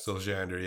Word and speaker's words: Siljander: And is it Siljander? Is that how you say it? – Siljander, yeah.